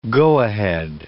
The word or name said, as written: go-ahead